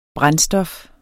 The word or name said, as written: brændstof